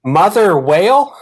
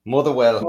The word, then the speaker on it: Motherwell: Mother whale? – Well. – Motherwell. – Oh, Motherwell. Okay, Motherwell.